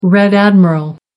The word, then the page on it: red admiral: American English